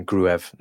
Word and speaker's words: Gruev: And good to actually have a definitive pronunciation on Gruev now as well. It's Gruev.